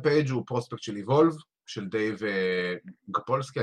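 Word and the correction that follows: Sapolsky: אני תמיד שוכר איך מבטאים את השם שלו. סופלסקי. סופלסקי. גייב סופלסקי. בדיוק, גייב סופלסקי.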